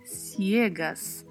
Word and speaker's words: ciegas: A letra E é sempre mais fechada e temos que ter muito cuidado para não deixar de pronunciar a letra I, ciegas, porque é a única diferença, é exatamente esta a diferença da palavra entre o português e o espanhol.